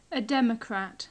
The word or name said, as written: a democrat